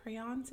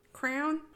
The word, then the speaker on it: crayon: It's two syllables. I'm not saying crown like a crown on your head. It's a crayon.